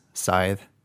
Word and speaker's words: Scythe: Since it's my number one, I think I have the authority to say the correct way to pronounce it, which is Scythe, not Scythe. – Yeah. – If we were to randomly poll our audience of one and ask them how to pronounce the word S-C-Y-T-H-E, I believe that they would say Scythe.